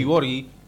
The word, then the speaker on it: Borghi: Además del pelado Grillo, en el Milan jugó Claudio Boggi, que después llegó al rojo. No sé si se pronuncia Boggi o Boggi. – Claudio Borghi.